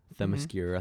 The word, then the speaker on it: Themyscira: Themyscira, mm-hmm. I think that's how you say it. – Yeah, you said it.